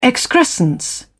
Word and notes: excrescence: British English

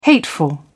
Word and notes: hateful: British English

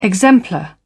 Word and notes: exemplar: British English

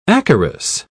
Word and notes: acarus: British English

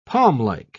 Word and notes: palmlike: British English

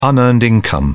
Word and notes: unearned income: British English

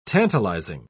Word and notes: tantalising: British English

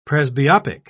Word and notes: presbyopic: British English

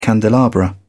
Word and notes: candelabra: British English

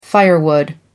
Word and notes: firewood: American English

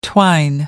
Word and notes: twine: American English

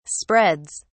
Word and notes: spreads: British English

